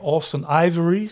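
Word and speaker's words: Austen Ivereigh: Eh, ik hoop dat ik het goed uitspreek. Austin Ivory, zeg ik dat goed? – Ja.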